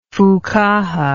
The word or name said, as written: fuqaha